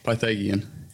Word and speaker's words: Pythagorean: Pythagorean. – Okay. – Pythagorean. – No one else knows either, so it's all right. – No, I had it almost right. I think I had a soft G.